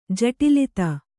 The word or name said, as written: ಜಟಿಲತೆ